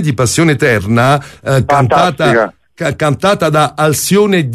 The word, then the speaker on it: Alcione: È in una versione di un'artista brasiliana che si chiama, come la pronuncia? Alcione. – Alcione, sì.